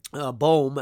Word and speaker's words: Boom: Corrie ten Boom, Boehm actually is how it's pronounced. Corrie ten Boehm had a statement that she used to say, and if you've never read her life, I would encourage you to do so.